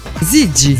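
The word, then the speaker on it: Ziggy: Abbiamo appena ascoltato Gigi Marley. – No, attenzione, no, Gigi. – Zigi.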